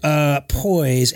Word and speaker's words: Poiesz: Mark Poise, and his last name is spelled P-O-I-E-S-Z.